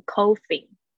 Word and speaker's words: coughing: 它这个 o u 是发 a，它是那个倒 v 的那个，它跟那个 bus 那个 u 是发一样的声音。 coughing，coughing。哦，刚才我听的好像你念成 coughing，不是 co，c，coughing，coughing。